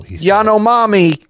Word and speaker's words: Yanomami: A lifestyle choice inspired by spending 2 weeks in the Amazon with the indigenous Yanomi people. – Yanomami. – Oh. – Say it right. Yanomami.